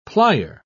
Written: plier

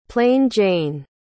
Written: plain-jane

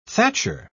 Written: thatcher

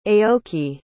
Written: aoki

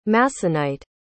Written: Masonite

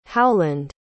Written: howland

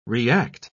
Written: react

